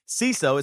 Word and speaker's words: Seeso: So let me tell you, CISO, it's spelled S-E-E-S-O, and it's an ad-free streaming service bringing you hilarious shows, hilarious comedy, and original series.